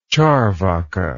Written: charvaka

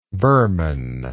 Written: Burman

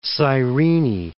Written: Cyrene

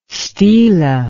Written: stela

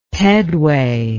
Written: pedway

